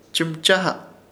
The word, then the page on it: Chɨmchaha: Ishir Chamacoco